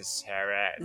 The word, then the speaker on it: Zara: que no se dice Zara, se dice Zara.